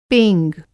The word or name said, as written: पेंग